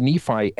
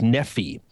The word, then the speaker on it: Nephi: Now, we as 21st century Americans and, of course, 20th century Mormons have pronounced this as Nephi, but sometimes the I on the end of Nephi can be pronounced as an E, as a long E.